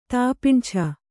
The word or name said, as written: ತಾಪಿಂಛ